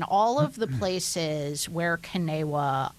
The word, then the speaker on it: CNEWA: Well, you pronounce it 100% correctly. Kanewa, C-N-E-W-A, Kanewa. – Kanewa. So the spelt with the C sounds like a K. – Well, C like as you would start with the word Catholic.